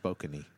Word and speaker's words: Spokane: It's pronounced Spokany.